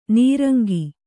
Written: ನಿರಂಕ

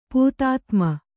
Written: ಪೂತಾತ್ಮ

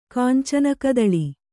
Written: ಕಾಂಚನಕದಳಿ

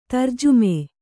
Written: ತರ್ಜುಮೆ

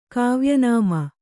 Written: ಕಾವ್ಯನಾಮ

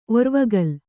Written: ಒರ್ವಗಲ್

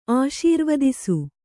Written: ಆಶೀರ್ವದಿಸು

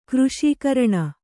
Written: ಕೃಶೀಕರಣ

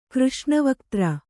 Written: ಕೃಷ್ಣವಕ್ತ್ರ

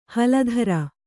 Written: ಹಲಧರ

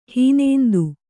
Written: ಹೀನೇಂದು

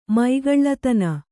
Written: ಮೈಗಳ್ಳತನ

